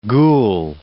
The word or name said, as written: ghoul